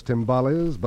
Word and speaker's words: Tymbales: Here's the Bob Bain Quartet, and the tune you know is Latin, because it rhymes with tamales. Its name is Tymbales, but unlike tamales, you don't eat them, you beat them, because tymbales are little drums.